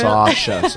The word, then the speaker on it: Sasha: And I actually was writing some notes, and I spelt your name S-A-W-S-H-A, so really? Sasha. So at least I would know how to pronounce it right.